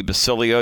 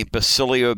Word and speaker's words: Basilio: In the meantime, I do want to say my name is Tony Basilio. You can find me on Twitter at Tony Basilio, B as in boy, A-S-I-L-I-O.